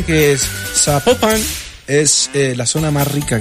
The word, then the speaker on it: Zapopan: Oiga, nos escriben los oyentes y que sapo, no se pronuncia Zapopan, sino que se pronuncia Zapopan.